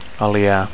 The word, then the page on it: aliar: Catalan